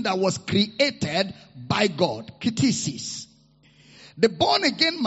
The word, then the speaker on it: ktisis: That word creature is the Greek word ktisis.